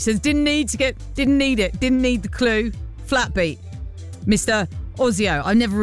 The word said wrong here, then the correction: Oizo: Oizo? It's not Ozio, is it? It's O-I-Z-O.